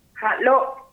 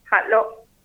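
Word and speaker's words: hal'o: Por ejemplo, para decir árbol en Wichí, ¿cómo se pronuncia? ¿Cómo se dice? – Halo, HALL, el apóstrofe y la O, halo.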